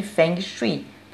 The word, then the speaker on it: Feng Shui: Sou consultora de Feng Shui pronuncia-se feng shui, desde a Eco 92.